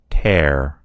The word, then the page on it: tear: American English